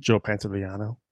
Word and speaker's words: Pantoliano: Joe Pantoliano.